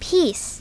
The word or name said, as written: piece